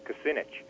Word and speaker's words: Kucinich: I think if you look back in presidential UFO history, you'll see that, and the way I pronounce it is Dennis Kucinich.